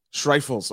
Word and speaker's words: Schreifels: It's German, so it's Schreifels. – Schreifels.